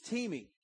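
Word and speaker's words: timi (τιμή): Again, this word honor in the Greek is timi, T-E-E-M-E-E. It's what it sounds like, but it's spelled time, T-I-M-E, but it says timi, and it means to value.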